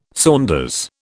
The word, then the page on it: saunders: British English